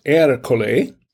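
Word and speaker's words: Ercole: I tell you what, if you're going to have a wine and cheese tasting party, I think this Erica, it's pronounced Ericole, is a good wine.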